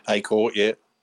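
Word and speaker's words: Accor: At Acorp, am I saying that right? – Acorp, yeah.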